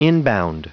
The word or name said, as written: inbound